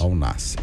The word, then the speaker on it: Al-Nassr: O Cristiano Ronaldo, uma última informação, acaba de sair aqui no GE.globo, Cristiano Ronaldo pode virar dono, como é que fala o nome do time lá dele? É Al-Nassr. Al-Nassr? É isso? – Ao Nasser.